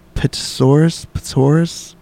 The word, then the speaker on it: Pterosaurus: Although considered the largest flying bird compared to Pterosaurus. I don't know if that T silent. Yeah, Pterosaurus. Fuck. Pterosaurus. – I think the P is silent.